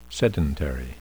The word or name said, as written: sedentary